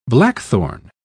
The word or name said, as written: blackthorn